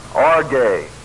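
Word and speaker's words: orge (ὀργή): O-R-G, long E, orge.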